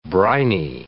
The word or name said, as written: briny